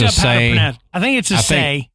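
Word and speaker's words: assay: Now, the essay office, come on, essay. – Yeah, I think it's a say.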